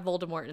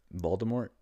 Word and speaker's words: Voldemort: In the audiobook, it is Voldemort. The really? – T is silent.